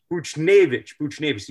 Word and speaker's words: Buchnevich: I'm going to mess up the pronunciation, but is it Buchnevich? – Buchnevich.